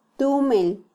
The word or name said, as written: tumel